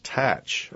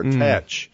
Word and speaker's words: Thatch: But even the word Thatch would not have been pronounced Thatch in 1718. It would have been pronounced Tatch or Tetch.